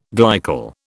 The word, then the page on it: glycol: British English